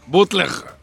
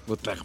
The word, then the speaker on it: Bootleg: Se llama Bottling Fire. Ay, a ver, ¿sí me lo entendí? No, no. Fire. ¿cómo era? Bootleg Fire. ¿Bootleg? Bootleg Fire. ¿Cómo se escribe? B-O-O-T-L-E-G.